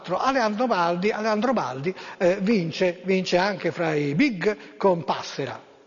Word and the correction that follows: Passerà: No, c'è l'accento. Passerà, quella lì.